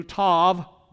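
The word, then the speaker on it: tov (טוב): And that word good, pronounced in Hebrew, tov, T-O-V, tov, good, that word good has a very special meaning.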